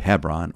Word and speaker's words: Hebron: David is made king at Hebron. The word Hebron, or pronounced sometimes Hebron, literally means the seat of association or fellowship.